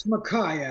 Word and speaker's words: Makaj: It's Makai, actually. – Makai, excuse me. – Doesn't look like Makai with that J there on the end, but that's how we've pronounced it since I was born. Well, now, it sounds like a Scottish name. Makai is a Scots name, M-C-K-I-E or M-A-C-K-A-Y. With the J, it's actually Polish.